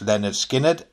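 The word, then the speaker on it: Lynyrd Skynyrd: Leonard Skinner, pronounced Leonard Skinner, and Uriah Heep's with Sweet Freedom.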